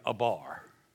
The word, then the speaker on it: abar: That word overlook used there in the Hebrew, it's the word abar, A-B-A-R. It sounds exactly like it's spelled, abar.